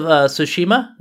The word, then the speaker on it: Tsushima: That Ghosts of Tsushima, is that how you say it? – Yeah.